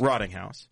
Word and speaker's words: Rottinghaus: So uh, we're going to have Mason uh, Mason Rottinghaus on, or whatever, however you say his name. Rottinghaus. – Rottinghaus. Yes.